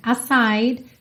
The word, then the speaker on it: aside: Aside, survive, elas rimam e o E final não é pronunciado em nenhuma dessas duas palavras, ok?